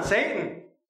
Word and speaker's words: Satan: The accuser, the Satan, Satan. That's how you say it in the original language.